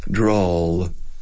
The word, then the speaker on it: drawl: Like draw, dibujar con L. Drawl.